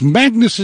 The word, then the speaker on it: Magnus: Well, it's Magnus is the Latin pronunciation, Magnus Heystek. If my Afrikaans friends will call me Magnus. – Yeah. – My mother would call me Maggie, but generally people talk to me as Magnus.